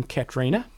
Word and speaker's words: Catriona: Katerina Morrison, who recently won the 70.3, so apparently you do pronounce the name Katerina, where it looks like it should be pronounced Catriona.